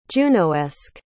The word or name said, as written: junoesque